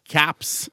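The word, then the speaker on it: Kopps: Nei, jeg så Cops på DVD. – Cops, altså den morsomme svenske filmen? – Nei, Caps. – Og jeg tenker på Cops. – Ja, Cops. – For det uttales Caps.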